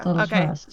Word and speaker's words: Delatraz: Why do you pronounce the Z? – Do you not? – No. Now I'm questioning which one of us says it wrong. – Louis Delatraz. – Yeah. Delatraz.